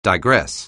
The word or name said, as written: digress